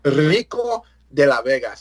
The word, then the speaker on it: Rico de la Vega: It's the R. You roll that R. It's not Rico. It's not that soft American stuff. It's Rico de la Vega. Say it with some dignity.